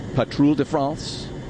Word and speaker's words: Patrouille de France: We have the French Patrouille de France. – That sounded good. – Yeah, I'm getting better and better at pronouncing that.